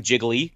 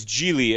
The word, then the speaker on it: Gigli: He's calling him Jiggly. And he's like, no, it's Gigli. It rhymes with really, which why do you create a name of a character that A, sounds so unappealing.